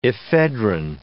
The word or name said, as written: ephedrine